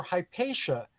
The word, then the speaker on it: Hypatia: The Greek pronunciation. But when I called her Hypatia, she said to call her Hypatia. And I said, why Hypatia? – No. – Because that's what it is in English.